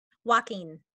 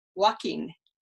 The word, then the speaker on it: Joaquin: Joaquin. – Joaquin. Joaquin. Which I would go, not Joaquin. – Joaquin. Yeah, it's a Spanish name, I believe. Yeah. There's an American actor, Joaquin Phoenix, which would help you if you are into actors, but you're not.